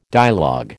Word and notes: dialogue: American English

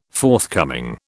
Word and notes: forthcoming: British English

